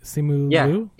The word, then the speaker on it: Simu Liu: But that's all coming from, I guess it's coming from both of them, from Awkwafina and is it Simu? Am I saying that right? – Simu Liu.